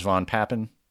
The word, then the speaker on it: von Papen: You point back to this aristocratic chancellor of Germany, Franz von Papen.